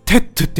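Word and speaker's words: tête: うの後ろにエステーってシーンが 2 つ続くとみなして、テットって読めんねんな。俺らめっちゃ頭良くないみたいなやりとりがあったかどうかはわかりませんが、とにかく S を発音せず、テットという綴りになった今でも、ウーアクソンシフコンフレックスの後ろには S があるとみなすのです。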